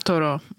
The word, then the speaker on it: Thoreau: Thoreau. – Mm-hmm, Toro. – Toro.